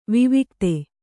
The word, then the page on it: ವಿವಿಕ್ತ: vivikta